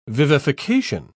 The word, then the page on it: vivification: British English